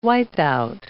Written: wipeout

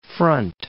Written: Front Range

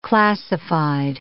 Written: classified

